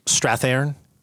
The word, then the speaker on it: Strathairn: Strathairn. I know, but please, with your acting pronunciation. – Correct.